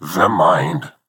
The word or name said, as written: The Mind